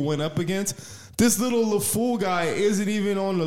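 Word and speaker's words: LeFou: You think this little, how do you say, Le Fou? What's the guy? How do you say? – Le Fou.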